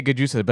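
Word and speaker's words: Gjusta: It's spelled G-J-U-S-T. – Yeah. – And if you say Giusta, like, it's Giusta. It's like, hey, fuck you. You created this word. You can't create a word and then put a silent letter in it, assholes.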